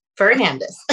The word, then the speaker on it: Fernandez: So it is spelled J-O-M as in Mary, A-L-L-Y Fernandez with an F as in Frank, not Hernandez. Fernandez.